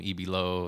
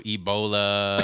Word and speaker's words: EB Low: So I was, you know, unsatisfied with the name EB Low. I was like I would always get people Ebola and just crazy mispronunciation.